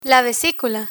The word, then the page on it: vesicula: spanish